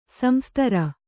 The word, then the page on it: ಸಂಸ್ತರ: samstara